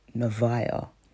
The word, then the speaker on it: Nevaeh: So is it Nevaeh? Nevaeh, Nevaeh, Nevaeh, it's Nevaeh.